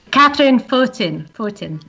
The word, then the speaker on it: Catherine Fortin: Catherine, how do you say your name? – Catherine Fortin, I'm English.